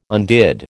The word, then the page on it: undid: American English